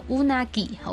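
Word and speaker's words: うなぎ: 因为鳗鱼在日文开头就是乌的发音，好，乌纳吉，好，乌纳吉，鳗鱼。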